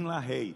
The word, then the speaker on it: LaHaye: Eu sei que os irmãos gostam de falar LaHaye, mas na verdade a pronúncia, quem quiser falar com sotaque inglês, é Tim LaHaye.